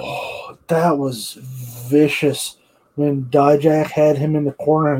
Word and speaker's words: Dijak: I don't know if I said that right or not. Dijak, yes.